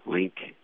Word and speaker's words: Link: Link, L-I-N-K, and I'm a lieutenant commander, Navy.